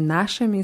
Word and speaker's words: нашими: By нашими ist die Betonung auf A. «а», с нашими соседями.